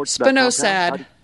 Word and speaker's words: spinosad: One more spinosad.